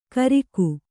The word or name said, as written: ಗುರಿಕೆ